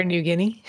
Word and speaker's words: Niugini: Niugini. N-I-U-G-I-N-A. So the Guinea is easy. It's how you pronounce N-I-U. That's the problem. – Air Niugini? – Well, let's just call him that.